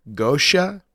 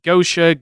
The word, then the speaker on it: Goetia: I've heard it pronounced Gosha, Geisha, Goetia. Like, I, I go with Gosha or Geisha.